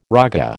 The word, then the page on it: raga: American English